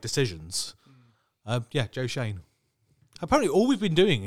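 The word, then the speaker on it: Schoen: That's not how you pronounce Shane. – Schoene. – I checked the spelling on that one as well. – Joe Shane. It is Shane.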